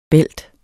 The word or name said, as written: bælt